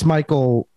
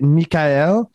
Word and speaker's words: Michael: They flip the E and the L, and then they pronounce it wrong again somehow. Like they instead of A-E-L for Michael, they go E-A-L, and it still doesn't make sense phonetically. Like they still say it Mikael.